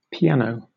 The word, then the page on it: piano: British English